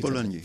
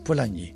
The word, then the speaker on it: Polanyi: Hoe spreek je Polanyi. – Dat? Polanyi. Een politiek econoom uit de vorige eeuw die het liberalisme al bekritiseerde voordat het neoliberalisme werd.